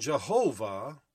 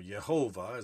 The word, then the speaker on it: Yehovah: And Jehovah, or Yehovah, as I prefer to pronounce it because there's no J sound in Hebrew, says, has laid in him the iniquity of us all.